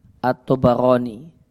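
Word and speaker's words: At-Tabarani (الطبراني): Cara baca yang benar, ba'nya di fathah. At-Tabarani. Bukan di sukun. Bukan At-Tabarani, namun At-Tabarani.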